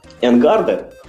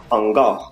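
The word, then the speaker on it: En Garde: Я, правда, узнал от Александра, что это на самом деле читается с французского «ангар». – Да. Вот. – вот. да, я, да. я не знал, я, я всю жизнь называл ее «Энгарде».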